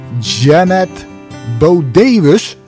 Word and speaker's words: Janet Bodewes: Ik had vorige week in Jukebox een interview met Janet Bodewes. Ja, ik moet goed uitspreken, want ik werd op mijn vingers getikt. Maar zo spreek je dat dus uit.